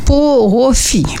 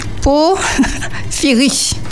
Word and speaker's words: porphyrie: Sauf peut-être une seule maladie très rare du métabolisme sanguin, donc la pro, pro la, alors j'essaie de bien le prononcer parce que j'ai tendance à aller trop vite, qui s'appelle la porophie. Porphyrie.